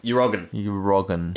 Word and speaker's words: Erdoğan: Erdoğan.